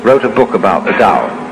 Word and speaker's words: Tao: So their principle of nature is called a Tao, T-A-O, pronounced Tao in the Mandarin dialect, Tao in the Shanghai dialect, To in the Cantonese dialect. Take your choice.